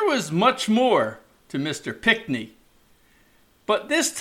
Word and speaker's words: Pinckney: Now, I say Pinckney, although there seems to be an extra N in the name, but that's how the locals I met pronounce it.